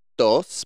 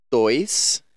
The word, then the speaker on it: dois: So not dos, but dois.